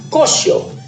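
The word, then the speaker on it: cochon: y donde cochón es puerco, impuro, sucio, detestable, una palabra peyorativa que se pronuncia cocho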